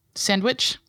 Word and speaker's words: sandwich: Sandwich? – Sandwich. – Do I say it like I say important? – It's sandwich. Like the sandwich. D is super soft.